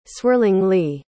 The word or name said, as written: swirlingly